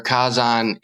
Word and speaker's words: Kazan: Well, you know, for the listeners out there, Kazan is the romanization and pronunciation of volcano in Japanese.